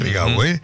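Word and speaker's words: Rigau: El silencio de la política me hace acordar al silencio de la política con el caso de Chocolate Rigaud, que se pronuncia Rigaud, porque no es francés, ¿no?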